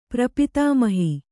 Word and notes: ಪ್ರಪಿತಾಮಹಿ: prapitāmahi

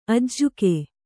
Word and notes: ಅಜ್ಜುಗ: Kannada

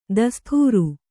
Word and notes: ದೋಸ್ತಾರ: dōstāra

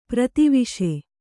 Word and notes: ಪ್ರತಿವಿಷ: prati viṣa

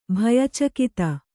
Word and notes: ಭಯಚಕಿತ: bhaya cakita

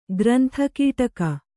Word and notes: ಗ್ರಂಥಕೀಟಕ: grantha kīṭaka

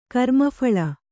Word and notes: ಕರ್ಮಫಲ: Kannada

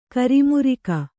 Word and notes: ಕ್ರಿಮಿರಾಗ: kannada

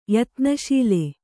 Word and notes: ಯತ್ನಶೀಲ: yatna śila

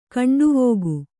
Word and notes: ಗಂಡುವಿಕ್ಕೆ: kannada; gaṇḍuvikke

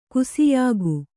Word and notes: ಗಾಸಿಯಾಗು: gāsiyāgu

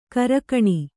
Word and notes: ಕರಕಣಿ: karakaṇi

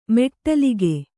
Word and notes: ಮೆಟ್ಟಲಿಗೆ: meṭṭalige

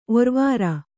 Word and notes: ಒರುವಾರ: oruvāra

